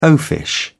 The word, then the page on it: oafish: British English